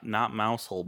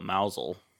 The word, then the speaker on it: Mousehole: Not mousehole, but mousel. – Mousel.